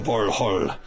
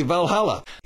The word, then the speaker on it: Valhall: sort of screaming as they take them to Valhall, which is, you know, actually the correct way to say Valhalla.